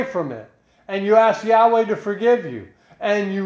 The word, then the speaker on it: Yahweh: Yahweh. That's how you say it, the Tetragrammaton in Hebrew. I am Yahweh. I am Yahweh. Y-H-W-H.